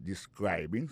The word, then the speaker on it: describing: Ça nous ka wè là, ça nous constate là, nous ka constate que li a prononcé mot de describing. Accent tombé sous deuxième syllabe là. Ça veut dire mot ça gen trois syllabes describing.